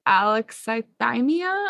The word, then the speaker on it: alexithymia: And I just wanted to say really quickly, something else that came up in the training was this concept of alexithymia.